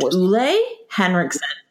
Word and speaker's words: Ole Henriksen: I just learned that this is pronounced Ole Henriksen. Yeah, because he's from Copenhagen, you know, like the whole hug a trend, whatever.